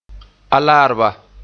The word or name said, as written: Alaarba